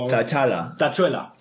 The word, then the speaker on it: T'Challa: Porque, como he relatado en una reciente entrevista, pudo haber sido Chala, o como se pronuncia, pues yo reconozco... – Chulu. – T'Challa, no sé si le conozco... – T'Challa.